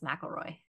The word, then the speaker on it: McElroy: It looks like McElroy, but it's pronounced McElroy.